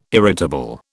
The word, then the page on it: irritable: British English